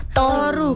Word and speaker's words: Toru: Toru, Kak. Karena mengambil dari bahasa Jawa, turu.